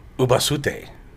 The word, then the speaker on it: Ubasute: So this is called, I don't know if I'm saying it right. Sorry if I'm not. Ubasute. I believe so, that's right. That's pretty. – Sounds so pretty close.